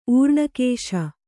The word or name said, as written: ಊರ್ಣಕೇಶ